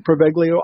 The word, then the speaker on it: Poveglia: And it's known as, correct me, Chris, if I'm wrong, but it's pronounced Poveglia Island. – Yes, it is.